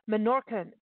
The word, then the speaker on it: Menorcan: You're talking about a cuisine that is the Menorcan culture. Am I saying that correctly? Because this is yes. – a whole new thing for me. Okay. – Yes, Menorca.